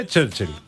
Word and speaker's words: Churchill: Ακούσαμε και στο ηχητικό, υπάρχουν κάποιοι οι οποίοι ξέρουν πώς να τον προφέρουν, υπάρχουν και κάποιοι άλλοι οι οποίοι δυσκολεύονται. – Έλα, κάνε. – Κώστας Καραμαλής, ας πούμε, Τσέρτσιλ. Κυριάκος Μητσοτάκης, Τσέρτσιλ. Ευκλήδης, Τσέρτσιλ. Οι άλλοι δεν το έχουν μάθει ακόμα.